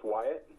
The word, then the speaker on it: Wyatt: Sure, it's Wyatt. – How do I spell that? – W-Y-A-T-T. – W-Y-A-T-T, okay.